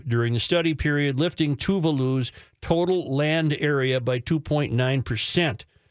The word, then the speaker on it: Tuvalu: A University of Auckland study examined changes in the geography of Tuvalu's, I don't know how to say it. – Tuvalu-Valua? – No. The geography of Tuvalu's 9 atolls and 101 reef islands between 1971 and 2014 using aerial photographs and satellite imagery.